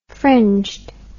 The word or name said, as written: fringed